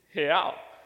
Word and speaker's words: real: See, in Portuguese, the way that you say real is real. So let me teach you how to say it. So on the count of three, let's say he. So it's he, but without the E sound.